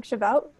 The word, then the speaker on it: Shavuot: And I believe, I'm probably going to say it wrong, Shavuot.